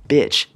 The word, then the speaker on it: bitch: The first one, bitch, b i t c h. That i is hard to pronounce for a lot of Chinese people. 很多中国人就是发音就是说那个 i 发音有问题。应该是 e. i。It's really hard. You have to kind of like widen your mouth，就是把那个嘴巴就变宽一点。i eh. eh. eh. i i i i i b b b. Okay, can you hear that?